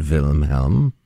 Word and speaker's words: Wilhelm: Connect Warren Wilhelm Jr. I prefer to pronounce it with the correct German pronunciation, Warren Wilhelm.